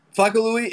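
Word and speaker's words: Flaco Luis: Um, Flaco Louis. It's really like whatever people is easier to pronounce because my name is Luis, so I just took the S off, and uh, usually people call me Flaco or Louis or does the whole entire thing.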